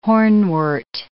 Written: hornwort

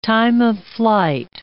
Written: time-of-flight

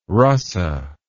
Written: rasa

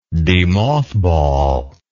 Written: demothball

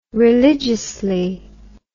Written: religiously

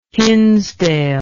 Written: hinsdale

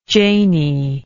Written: janie

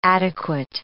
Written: adequate

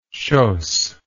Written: chausses

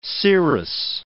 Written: serous

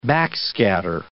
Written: backscatter

